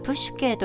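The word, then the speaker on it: プ シュ ケ (ψυχή): 今月のメッセージはサイキックという言葉のもとになったサイキ、つまりプシュケについてです。心理学を意味するサイコロジーという言葉にもつながっています。古代ギリシャ語ではプシュケと発音して、魂、心、生命などを意味します。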